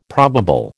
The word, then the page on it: probable: American English